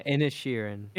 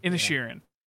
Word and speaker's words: Inisherin: I watched Banshees of Inisherin? Don't know how to say it. I'm not going to try it in the Irish accent. That's not. – Yeah, Inisherin. – Inisherin.